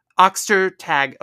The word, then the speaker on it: Ostertag: Okay, so seriously, I learned of this book after reading the exceptional Substack of Molly Knox Ostertag, Oxter tag. Okay, there we go. I knew I said it right.